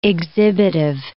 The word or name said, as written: exhibitive